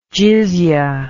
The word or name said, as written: jizyah